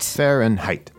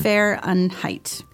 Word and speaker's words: Fahrenheit: And I just want to talk a little bit about the pronunciation of Fahrenheit again. – Mm-hmm, yeah. – So it's like Fahrenheit. – Fahrenheit. – Right. – Yeah. – So lots of extra letters there, but Fahrenheit.